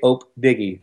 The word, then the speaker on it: OpDiggy: Yes, I perform mostly nowadays under the name of DJ Op Diggy. That's spelled O-P-D-I-G-G-Y.